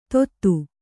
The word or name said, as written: ತೊತ್ತು